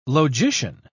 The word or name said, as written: logician